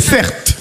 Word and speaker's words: Certe: Mon prénom, c'est Certes. Ça s'écrit C-E-R-T-E.